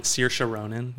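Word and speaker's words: Saoirse Ronan: Or Sir Sharonan, you know? – No, I don't.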